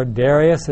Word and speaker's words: Darius: Darius, or Darius, as some people would pronounce it.